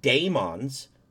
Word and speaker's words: daemons: If you look at it, again, from the Carl Jung sense, the daemons, which is a different form of... it's just different from demons. D-A... – You're saying that like Cheats McGee would say that. – Well, no, it's pronounced that way. D-A-E-M-O-N is...